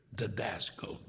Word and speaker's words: didasko: Now, he says teaching from the Greek word didasko, didasko. Again, it's spelled D-I-D-A-S-K-O, but it's pronounced D-E. The I sounds like an E.